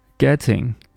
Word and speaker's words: getting: Getting 中间的这个 T sound，我们把它用 flap T 进行处理。